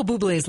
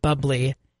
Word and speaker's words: Bublé: So if you go online and you look up how to pronounce Michael Bublé's last name, it says Michael Bublé's last name is pronounced Bublé. It does not sound like bubbly.